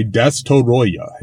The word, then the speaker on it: Destoroyah: Now, regarding the name Destoroyah, in Japanese, his name is pronounced phonetically, and in the dub, he is called Destroyer. So, if you listen to the Japanese, when they say Destoroyah, if you listen to the dub, the international dub, they say Destroyer.